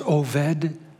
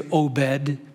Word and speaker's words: Oved: By the way, in the Hebrew tongue, it's pronounced Oved. So you and I say Obed because of the B there, but there's no B sound. It's V, Oved.